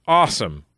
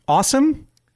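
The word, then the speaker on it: Asum: A, SUM, pronounced awesome. – Ah, wait a minute. A, lowercase SUM. – Okay. And it's pronounced awesome? – Yeah, or Alcoholics Anonymous some.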